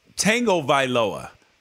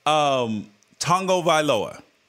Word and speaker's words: Tagovailoa: His name is Tua, as he put in quotes, Tagovailoa. Yet you two assholes regularly and continuously mispronounce it when you say Tua Tagovailoa, and there's no tango in his name.